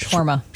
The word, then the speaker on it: Shawarma: Shawarma.